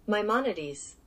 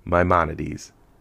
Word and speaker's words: Maimonides: So my quote today comes from Maimonides. – I had to do that for the pronunciation. Maimonides.